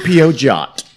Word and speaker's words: Peugeot: Ooh, you know how to pronounce that. – Yes, I do. – Peugeot.